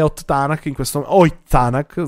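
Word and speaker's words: Ott Tänak: Ott Tanak sarebbe la pronuncia giusta, è normale che si spinge un po' di più.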